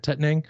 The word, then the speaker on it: Tettnang: using Tettnang and Tettnang, that's how you say it, Tettnang, and Spalt hops.